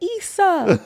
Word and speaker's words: Issa: She said Issa Rae and everybody was like, Issa!